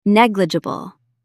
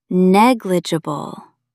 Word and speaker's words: Negligible: Stress the first syllable. Negligible.